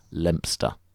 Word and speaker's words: Leominster: Leominster isn't pronounced how it's spelt. Like Worcester and Towcester, you can ignore most of the letters and just say Leominster.